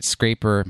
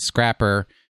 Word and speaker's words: Scrapper: I pronounce it Scraper. Most people pronounce it Scrapper, to remove the tool from your Garbodor.